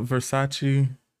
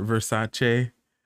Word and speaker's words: Versace: Yeah, but yeah, Crystal Versace or Versace. That's Donatella Versace herself said that is how you say it, but I think we all are so used to saying Versace. That's how we say it.